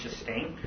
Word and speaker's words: Kerstin: Shastain Goldsmith, and she spells her name Kerstin, K-E-R-S-T-I-N, but it's pronounced Shastain.